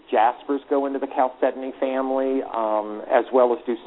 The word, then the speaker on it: chalcedony: Talk to me about, and I'm never sure I pronounce this right, chalcedony? – Chalcedony? – Chalcedony.